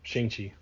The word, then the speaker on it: Shang-Chi: How do you pronounce the one? The Master Kung Fu? – Shang-Chi.